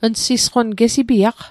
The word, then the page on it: gesipiaq: Mi'gmaq